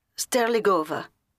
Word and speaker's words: Sterligova: Marta, as in M-A-R-T-H-A? – That's right. – And your surname? – Sterligova. – Can you spell that? – Yes, it's S-T-E-R-L-I-G-O-V-A. – Sorry, is that S-T-A-R, A as in Amsterdam, or S-T-E-R-E as in Egypt? – E as in Egypt.